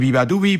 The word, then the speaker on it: Beabadoobe: Ay, bueno, no me extraña que se buscara un nombre artístico todavía más complicado, si acaso, pero se escribe Beabadoobe y se dice Viva Dubi. Y de Viva Dubi vamos a Sade Adu,